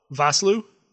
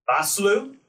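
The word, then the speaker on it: Vosloo: Arnold Voslo. – Voslu. – Voslu. – Yeah, playing Imhotep. – Voslu.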